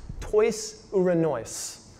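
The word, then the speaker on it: tois uranois (τοῖς οὐρανοῖς): Now, the, the, the Greek word for heaven here is pronounced tois uranois.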